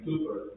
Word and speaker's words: Cowper: There was a man named William, and if you want to sound really smart when you talk to people about this, pronounce his name Cooper, and they'll say, no, it's Cowper, and you can say, no, it isn't Cooper. Because it really is Cooper.